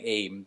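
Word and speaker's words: Eyam: Eam. I think that I Googled how you pronounce it. It's spelled E-Y-A-M. I'm saying Eam.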